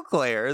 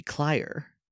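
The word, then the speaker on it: Clare: It's still Claire. The E makes the A long. – Then why does most of the time it have an I there? – Sometimes you could do it without the E. – Maybe. Oh, that's true. Maybe. And if I guess with the I, that'd be Clyer. – Clyer.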